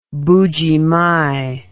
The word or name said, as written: mbuji mayi